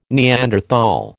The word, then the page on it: Neanderthal: American English